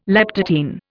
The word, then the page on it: leptotene: Indian